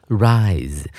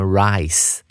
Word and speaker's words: rise: 接着我们来看第四个单词 rise，这个字的发音要特别注意啊，不要念成 rice 啊，就会变成稻米啊，或者是米饭的那个念法哦，r i c e rise 啊，那个 z 啊，稍微念 z 的发音出来一点点就对了。